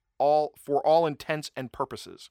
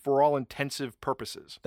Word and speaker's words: for all intents and purposes: So I hate when people pronounce or mispronounce for all intents and purposes. – Ooh, yes. – And they will say for all intensive purposes.